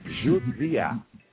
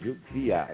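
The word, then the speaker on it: Jodi a: And I can't really, I don't know how to tell you to pronounce it, because the J-O sounds like Jew, and the D-I sounds like Z. So Judzi and then I, Judia.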